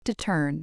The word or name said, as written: to turn